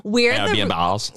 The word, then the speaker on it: Airbnb: Is that how you say it? – Airbnbs. Yes.